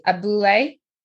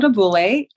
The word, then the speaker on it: Abule: Abule. Yep, perfect. Yes, it's it's called Abule, and Abule means village in my native language Yoruba, which is um, Nigerian.